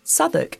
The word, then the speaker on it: Southwark: A quick tip for you non English Southwark is not spelt remotely as it's pronounced. Drop the W when you say it or check the notes.